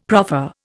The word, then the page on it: proffer: Indian